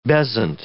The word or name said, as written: bezant